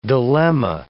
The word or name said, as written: dilemma